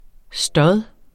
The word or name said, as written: stod